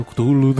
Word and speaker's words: Cthulhu: Dando uma ideia pra tema, o famoso Chulo Mitos, né? – É o famoso Cthulhu, né? Que, é. Na verdade, o nome Cthulhu, né, não, nem pode ser pronunciado pela garganta humana, né, mas é só na...